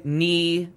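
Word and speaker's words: née: It would be Darren Young, knee Fred Roser. – Thank you. – There's that. – That's how you say it. You say knee, which means born as.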